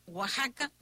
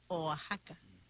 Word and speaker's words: Oaxaca: Si se dice Oaxaca, no, es Oaxaca, ¿no? De pronunciación, repítelo.